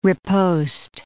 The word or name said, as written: riposte